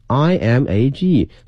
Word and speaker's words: image: Taste innovation innovation 表示创新， taste 口味创新， and image building 后面这个词非常重要， image image 首先它的发音呢，它的重音在前面， image 不要把重音放到后面了哈。